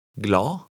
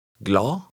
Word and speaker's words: glad: The D at the end is silent. Try it. – Glad. Glad. – The letter D is often not pronounced at the end of short words.